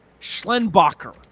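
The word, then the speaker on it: Schlenbaker: Schlenbacher. So, but it's spelled Baker at the end. So it's Schlen, S-C-H-L-E-N, and then Baker.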